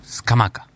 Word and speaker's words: Scamacca: Scamacca. – Qué bien que lo dice el fiscal. – Es como se pronuncia, no es que yo lo digo bien o mal.